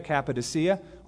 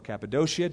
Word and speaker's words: Cappadocia: Parthians, Medes, Elamites, those dwelling in Mesopotamia, Judea, Cappadocia, or Cappadocia, depending on how you want to pronounce it,